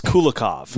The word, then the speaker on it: Kulikov: I definitely said it wrong. It's Kulikov. – No. Yeah, I think in Russian they actually say Kukulov. I think that's how they're weird over there. I think they just, you know, mess around with the K's and the L's.